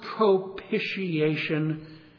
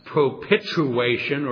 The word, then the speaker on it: propitiation: Some of you probably wonder, what does that word propitiation mean? By the way, that's the correct pronunciation, not propituation or propitiate. They somehow throw an L in there too.